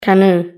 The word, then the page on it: canoe: British English